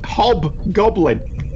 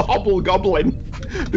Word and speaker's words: Hobgoblin: No, it's just Hobgoblin. – Oh. – Not the Hobblegoblin.